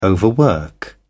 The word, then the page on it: overwork: British English